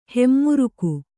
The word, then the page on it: ಹೆಮ್ಮುರುಕು: hemmuruku